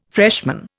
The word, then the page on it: freshman: Indian